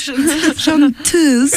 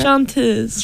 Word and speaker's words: chanteuse: Chanteuse. – Ooh, that was a good How do you think it's pronounced? – Chanteuse. – Chanteuse. – Oui.